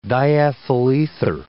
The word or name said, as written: diethyl ether